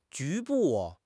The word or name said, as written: 局部